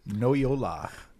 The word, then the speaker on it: Noyola: My name is phonetically spelled Noyola.